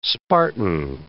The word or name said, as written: spartan